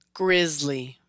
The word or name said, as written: Grizzly